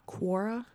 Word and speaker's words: Quora: Quora.